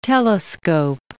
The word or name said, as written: telescope